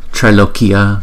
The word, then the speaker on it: Trailokya: Sarva Sarva Sarva Sarva Trailokya Trailokya Trailokya, chữ A với chữ Y là đọc là ai ai ai, là hai nhịp.